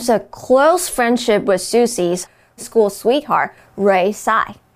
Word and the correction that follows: Singh: Uh, Singh. – Singh. No, yes, yeah, pronounced Singh.